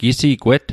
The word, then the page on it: gisigwet: Mi'gmaq